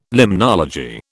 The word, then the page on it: limnology: British English